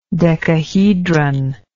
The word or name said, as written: decahedral